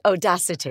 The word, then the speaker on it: Audacity: Do you know Odasite? I'm not saying it right. – No, it's Audacity. – Is it? – Yes.